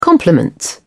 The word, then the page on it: compliments: British English